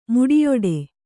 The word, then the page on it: ಮುಡಿಯೊಡೆ: Kannada